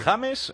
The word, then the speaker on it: James: Sí, nombre en inglés, pronunciación en español. Sí. James, ¿eh? – ¡Gol!